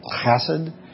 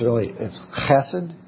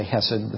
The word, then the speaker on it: chesed: It's more to it, and it sounds kind of funny. It's like you're trying to clear your throat when you say it. It's kind of a sound. So it's really like chesed. I can't say it like somebody who really knows Hebrew well, but it's chesed, and we just say hesed, but that's fine.